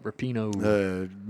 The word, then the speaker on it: Rapinoe: They won the – 1-2-0, Megan Rapinoe, is that how you say it? – Yep, Rapinoe.